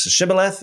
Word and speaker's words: Shibboleth: It's the same name, just pronounced different names, Shibboleth and Sibboleth.